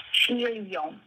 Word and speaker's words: Giglio: Here it's actually pronounced Giglio.